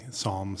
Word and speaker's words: Psalm: I don't know if you caught it earlier that today is Sunday, and the P is silent in that Sunday, Psalm Sunday.